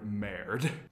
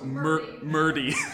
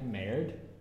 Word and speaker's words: merde: How many different ways did you try to pronounce the French word merde? Which means shit, by the way. – Well, I kept saying merde. – The worst one was Murdy.